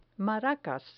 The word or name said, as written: maracas